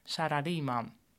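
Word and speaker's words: salaryman (サラリーマン): Neem het woord salaryman. Dat spreek je in het Japans uit als salaryman.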